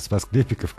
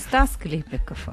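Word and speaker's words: Спас-Клепики: Можно еще одно дополнение с СМС-портала по поводу «Спасклепиков» как раз. Произносить надо «УАЗик Патриотик». – Главное, что не путайте не «Стас Клепиков», а «Спас Клепиков».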